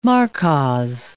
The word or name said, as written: markkas